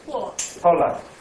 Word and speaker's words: Pula: I was in Pula. Pula? – Oh. – I didn't pronounce it right. – Pula.